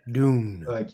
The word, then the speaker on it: Dune: En anglais, Dune.